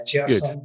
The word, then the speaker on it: Chersun: Is it Chersun? – It is indeed, yeah, Chersun Good.